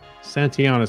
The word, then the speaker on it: Santayana: And George Santayana. – Santayana, excuse me. I threw an extra syllable in there.